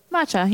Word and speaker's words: matcha: Matcha, yeah.